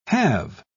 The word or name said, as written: halve